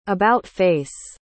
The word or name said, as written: about-face